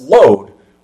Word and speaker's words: Lod: Well, it's mentioned a few times of one of the families in the Promised Land are mentioned there of being of load, L-O-D, pronounced load, which would be the family Load and Ono, not Yoko, were actual brothers that would inhabit that area of the region.